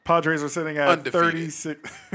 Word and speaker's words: Padres: I'm rolling the R. Padres. Got to flatten your tongue.